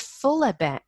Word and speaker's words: Furla: So maybe that type of consumer would choose something like the Fula brand. Do you know that brand? It's spelled F-U-R-L-A.